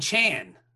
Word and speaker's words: Can: But they call him Chan. – Yeah. – Like Jackie Chan. – Yeah. – But it's not spelled with a J. – It's C-A-N.